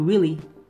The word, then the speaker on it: Weelee: Alright, you can call me Willie, or my friends, my close friends, call me Willie. it's kinda stupid, but it's spelled W E E L E E.